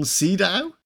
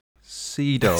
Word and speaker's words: Sydow: Max von Sydow? – Sydow.